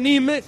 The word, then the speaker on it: Nîmes: Saludos, tiempo de juego de la cadena COPE desde Nîmes, que así se pronuncia más o menos correctamente el nombre de esta ciudad,